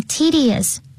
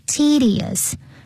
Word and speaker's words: tedious: 这边我们看到 approach 这个字 approach 在这里就是 deal with 也就是 handle How will you handle tedious routines 那这边 tedious 也很重要它的发音 tedious 它是长音哦 tedious 也就是 boring 的意思。